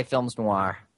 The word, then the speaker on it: films noirs: I'm pretty sure that you're not going to pronounce the S at the end of films noir.